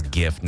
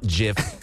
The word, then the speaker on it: GIF: It is a hard G. It yeah. – is GIF, not JIF. That's a peanut butter. It is GIF hard G.